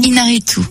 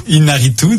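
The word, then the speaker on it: Iñárritu: Oui, mais moi aussi, Alejandro, j'ai du mal aussi. Irarritu. – Inaritu. – Inaritu,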